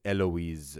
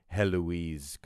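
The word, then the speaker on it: Héloïse: I mean, his name is Abelard, so it's like Pierre Abelard, and her name is Eloise. It's kind of tempting to say Heloise, because it's Louise, so Eloise.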